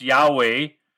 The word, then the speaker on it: Yahweh: So the name Yeshua communicates the understanding that God, Yahweh, that's how you pronounce W-H, it's pronounced Yahweh.